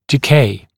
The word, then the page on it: decay: dɪ’keɪ; ди’кей